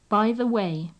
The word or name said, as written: by the way